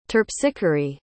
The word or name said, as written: terpsichore